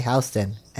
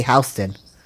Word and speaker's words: Houston: Well, hey, we got a street in New York that's called, it's spelled like Houston, but we say Houston, and it's absolutely Houston.